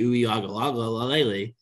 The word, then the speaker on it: Uiagalelei: But then you look at like a DJ Uiagalelei. That's not a that was half a stutter, half just I didn't know if I was saying his name right. – I think you nailed it, man.